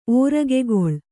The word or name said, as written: ಓರಗೆಗೊಳ್